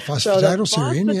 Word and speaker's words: phosphatidylserine: And there's those two ingredients, the passion flower and the phosphatidylserine, if I said that right. – Yeah, phosphatidylserine, yes.